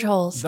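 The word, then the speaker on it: Holz: George Holes. – Okay, I was going to say, that is how you say his last name.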